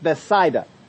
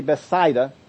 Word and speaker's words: Bethsaida: Now, if you want the actual real pronunciation, it is pronounced Bethsaida. There's a particular Greek diphthong there, which just means it's a word for two vowels when they come together. When those two particular vowels come together, they are pronounced like an aisle right down in here. I'll get that sound there. So it's actually Bethsaida.